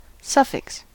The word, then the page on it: suffix: American English